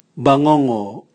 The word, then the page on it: bwangongo: Raga